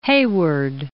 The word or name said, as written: Heyward